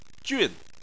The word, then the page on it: jun4: chinese